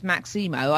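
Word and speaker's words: Maxïmo Park: And a revelation that it is pronounced Maximo. I did not know that until the lead singer said it. And then I realised I had been called it the Maximo all these years.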